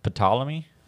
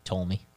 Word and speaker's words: Ptolemy: The brother was Ptolemy? – I don't think that's how you pronounce it. – How? – I usually say Ptolemy.